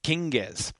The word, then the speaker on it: kinges: So, for instance, the plural of king, you say the G. Kinges.